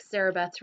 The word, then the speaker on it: Sarabeth: but it's Sarah Beth, like Sarah Beth's restaurant, so S-A-R-A-B-E-T-H. And then my last name is spelled like Jackson Pollock, because, you know, artist guy, so it's O-C-K and not U-C-K or I-C-K or any variation thereof. So it's just Sarah Beth Pollock.